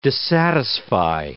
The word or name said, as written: dissatisfy